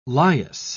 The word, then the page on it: lias: British English